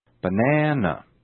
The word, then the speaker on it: banana: The following word has three syllables. Which syllable is stressed? Banana.That's right, the second syllable is stressed. Listen now for the lengthening of the vowel in the stressed syllable. Banana, banana.